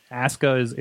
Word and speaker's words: Asuka: And Asuka, of course, and we talked about last week, Eamon schooling me on the you know the wording, you know, Asuka is the way that they pronounce it over there, despite the spelling.